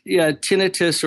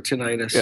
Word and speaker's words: tinnitus: Yeah, tinnitus or tinnitus. – Yeah. I'm Southern, so I'll butcher anything, really. – No, that's all right. You can't. And they're both pronunciations are acceptable, but yeah.